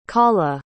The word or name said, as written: kaula